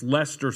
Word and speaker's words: Leicester: It's Leicester City.